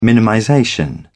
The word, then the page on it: minimization: British English